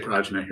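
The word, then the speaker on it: prajñā: How do you pronounce that in Sanskrit? – Pranya. – Pranya. We say Prajna here because we're in Michigan. But Bhaskar, who speaks Sanskrit, says Pranya.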